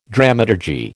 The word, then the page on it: dramaturgy: American English